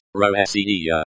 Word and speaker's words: Россия: The stress is on the second syllable, C. Think of it as Россия.